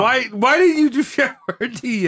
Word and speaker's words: Boyardee: Changed the way his name was spelt so people pronounced it Boyardee.